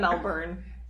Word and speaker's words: Melbourne: Melbourne? – Melbourne, yeah. – Melbourne? – Yeah, that place. – You said Melbourne. I got corrected so many times by Australian friends being like, it's Melbourne.